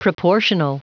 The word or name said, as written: proportional